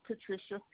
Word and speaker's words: Patricia: My name is Patricia Reed, R-E-I-D. – Thank you, Ms. Reed. Excuse me. Yeah, Patricia is spelled P-A-T-R-I-C-I-A.